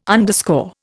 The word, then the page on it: underscore: Indian